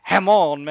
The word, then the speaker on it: Jamon: It's pronounced differently, but you guys aren't going to get this one, so we're just going to jump into it. Jamon Meredith, otherwise known as Hamon Meredith.